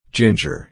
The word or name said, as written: ginger